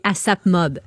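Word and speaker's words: A$AP Mob: Ben, c'est un gars de 30 euh, ans, là, il fait partie du collectif new-yorkais ASAP Mob. – ASAP Mob, OK. – Dans le fond, le ah. signe de dollar, là, tu le prononces comme un S.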